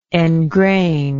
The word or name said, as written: engrained